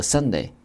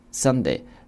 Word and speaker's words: sundae: 或者呢，除了这个 cone 之外呢，我们讲这个圣代叫做 sunday， sunday 这个单词啊 sundae 读作 sunday， sunday 圣代的意思。